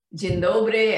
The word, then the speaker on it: dzień dobry: Let's see. I know "dzień dobry," and I know "jak się masz." Am I saying it correctly? – Yes. – That's correct.